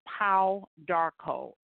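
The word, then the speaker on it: Pau d'Arco: And it's called Pau Darko. It's spelled P as in Paul, A-U. And the next word is Darko, D as in David, apostrophe A-R-C-O.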